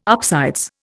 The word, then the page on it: upsides: Indian